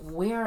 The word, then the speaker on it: were: Well, you know, it's spelled W-E-R-E, but it's pronounced wear and not were, because...